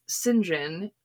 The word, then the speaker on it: St. John: And that was how she ended up with St. John, as it's pronounced in the original.